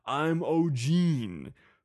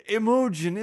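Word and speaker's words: Imogen: Which temporarily lost its way here when spelled and pronounced I'm Ogeen. Pronounced the British way, Emojin is as pretty and classy as it is distinctive.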